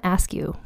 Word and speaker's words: Askew: Now, her last name is spelled A-S-K-E-W, like the word askew. But I asked on Twitter, and two people both said they think it's pronounced Askew. And I think that's how I've heard other people with that as a surname pronounce their surname.